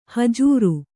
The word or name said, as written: ಹುಜರು